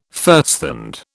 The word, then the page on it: firsthand: British English